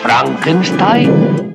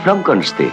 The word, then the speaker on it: Frankenstein: ¿Doctor Frankenstein? Frankenstein. Se pronuncia Frankenstein.